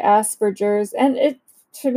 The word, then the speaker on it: Asperger's: But um, okay, first of all, the question that a lot of us have how do you pronounce Asperger's? I know that it's a hard G.